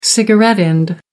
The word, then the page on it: cigarette butt: American English